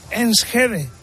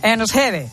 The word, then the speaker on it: Enschede: Enschede. – Bueno, visto? Enschede, que está Enschede.